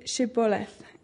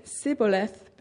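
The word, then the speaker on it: Shibboleth: If he replied no, they said, "All right, say Shiboleth." If he said Siboleth because he could not pronounce the word correctly, they seized him and killed him at the fords of the Jordan.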